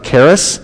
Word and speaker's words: charis: The root word for grace is charis in the Greek, where charity comes from. We pronounce it with a hard C-H.